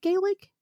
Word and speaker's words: Gaelic: So is it Gaelic? – Gaelic.